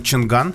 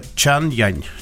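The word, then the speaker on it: Changan: Э, правильно произносить то, что мы называем чинган. Чинган. Да, но китайцы настаивают, что произносится это э, чан-янь. Чан-янь. Чан-янь. Да, пишется с твердым знаком и с мягким.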